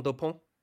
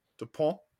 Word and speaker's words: Dupont: Antoine Dupont. – Dupont.